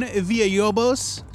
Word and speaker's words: Villalobos: You didn't hear me roll my R's. The art is by Ramon Villalobos. I'm sorry. – Lobos. – Villalobos. – Villalobos. – It's probably not Villalobos.